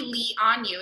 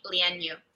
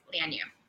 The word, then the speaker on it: Lian Yu: So it should not be Li on you. It should be Lian Yu.